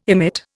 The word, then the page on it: emit: Indian